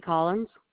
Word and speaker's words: Collins: Kari Collins.